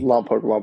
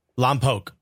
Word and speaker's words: Lompoc: Lompoc. – Lompoc. Okay. – Yeah. – If for everyone listening, it's Lompoc. You pronounce that without being all right. So you're from Lompoc.